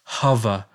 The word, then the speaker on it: Hover: And by the way, if my Australian English hybrid pronunciation of Hover is confusing, that's Hover for you Americans. Spell it H-O-V-E-R, like a hovercraft.